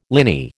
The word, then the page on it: liny: American English